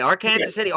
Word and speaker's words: Arkansas City: Wait, did I say that right? Arkansas? – Yeah, you said it right. Arkansas City, or Ark City is a slang term or a short term.